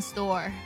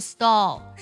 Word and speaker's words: stall: 这个单词读的时候呢，你千万不要读成 store，不要把这个舌侧音呢读成儿化音，因为这样就完全意思就变掉了，变成商店那个单词了。它读作 stall，舌尖呢最后是抵在你的上齿龈这里的 stall。